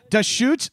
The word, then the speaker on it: Deschutes: Deschutes. The brewery is named Deschutes, like spelled like the river in Oregon, like D-E-S.